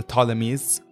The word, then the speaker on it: Ptolemies: So we all know that the Ptolemies are... – Ptolemies spelled P-T. – Yeah, with P-T. A silent P. – With a silent P, yeah.